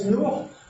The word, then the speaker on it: Noro: Parce que quand on voit l'orthographe, c'est écrit N-O-R-O, mais ça se prononce Nour en malgache.